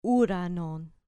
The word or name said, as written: uranon